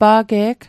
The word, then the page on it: pa'ge'g: Mi'gmaq